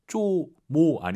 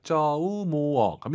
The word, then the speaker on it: 周末: 쪼모 아니고 오유는 어우예요. 쪼우모어 갑니다.